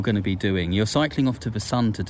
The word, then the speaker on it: scicling: It's cycling, and he spells it S-C-I-C-L-I-N-G. – Cambridge 105 Radio. – If you spell it, it sounds exactly the same as cycling. – Cycling. – But it's not spelled the same. It combines science, S-C-I, with cycling, the last bit of cycling, C-L-I-N-G.